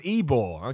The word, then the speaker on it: Ybor: It's pronounced Ybor.